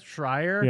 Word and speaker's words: Schreier: How do you say it? Schreier? – Yeah.